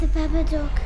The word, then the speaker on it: Babadook: The Babadook. – You can't get rid of the Babadook.